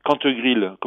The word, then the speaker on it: Cantegrille: C'est intitulé Le Rucher de Cantegrille, donc tout attaché, Le Rucher, comme ça c'est écrit, de Cantegrille, comme ça se prononce, C-A-N-T-E-G-R-I-L.